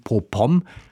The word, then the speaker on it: Popom: Ein unterhaltsames, humoristisches Meisterstück mit philosophischem Tiefgang, meint SWR-Literaturredakteur Carsten Orth über den Roman Popom. Heißt der Roman mit Betonung auf dem zweiten Ohr,